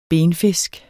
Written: benfisk

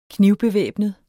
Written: knivbevæbnet

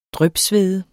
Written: drypsvede

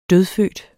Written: dødfødt

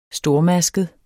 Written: stormasket

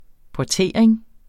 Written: portering

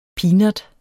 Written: peanut

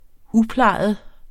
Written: uplejet

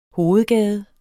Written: hovedgade

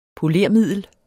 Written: polermiddel

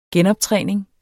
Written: genoptræning

